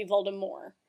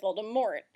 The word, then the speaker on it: Voldemort: Rowling, what she said was that her intention was that Voldemort be pronounced with a soft T. So it'd be Voldemort. – Mm-hmm. – And then basically it got like Americanized. – Okay. – And everyone pronounced it Voldemort.